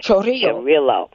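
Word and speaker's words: Chorrillo: And, you know, so I was very affected, especially as I followed that particular town, Chorillo, I believe. I used to know how to pronounce it. Get on it. – Chorillo.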